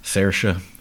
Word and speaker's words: Saoirse: Saoirse is a fun one. That's, you know. – Saoirse. – It looks like it's spelled Swarzy.